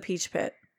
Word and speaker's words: Peach Pit: Obviously, it's the Peach Pit. I'm like, I can't believe I was saying that wrong.